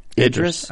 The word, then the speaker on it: Idris: Yeah, see, I think it's a little premature to be, and no offense to Idris Elba, but is it Idris? – Yeah.